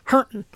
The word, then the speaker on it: Harrington: He just said Harrington, which is a town in this state, but it's pronounced hurting.